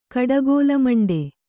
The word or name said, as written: ಕಡಗೋಲಮಂಡೆ